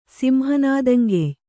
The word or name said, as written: ಸಿಂಹನಾದಂಗೆಯ್